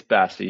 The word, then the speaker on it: Bassy: Is it Bassy or Bassy? – It's Bassy. You got it. – Good. I think, you know, like actual bass and bass.